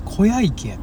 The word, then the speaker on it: 昆陽池: 小屋池やって小屋池やんな小屋小屋の里特別支援学校なんでねこれで小屋って読むんや昆布の紺に太陽のように池小屋池